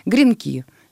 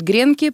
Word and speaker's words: гренки: Гренки. Все говорят гренки, правильно говорить гренки. И в единственном числе не гренка, а один гренок.